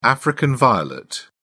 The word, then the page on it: African violet: British English